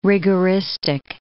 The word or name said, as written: rigoristic